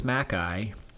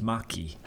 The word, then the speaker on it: Macchi: So our last name is Mackay, but that's only because when my people came over here a couple generations ago, they decided to change the pronunciation. But in Italian, it would be correctly pronounced Macchi.